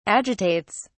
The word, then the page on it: agitates: British English